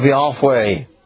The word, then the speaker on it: Yahweh: Like every time a mother, the wife of Cushi here, would call her son, she would say, Yahweh hides, or hidden of Yahweh. She would call that out when she was calling her son, Zephaniah. Yahweh is a two-syllable name, isn't it? It's disyllabic. It's got two syllables, Yah and Way.